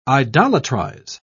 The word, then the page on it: idolatrize: British English